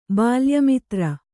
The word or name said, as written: ಬಾಲ್ಯಮಿತ್ರ